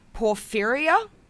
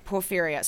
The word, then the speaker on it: porphyria: Porphyria. It's P-O-R-P-H-Y-R-I-A. He was previously thought to have suffered this condition, porphyria.